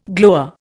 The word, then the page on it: glower: Indian